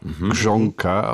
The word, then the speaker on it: Grzonka: Und ich habe jetzt eben das schon so ausgesprochen, das RZ, das ist natürlich ein weiches, wie in Garage, Grzonka, und dann spricht sich das schon nicht mehr so kompliziert aus.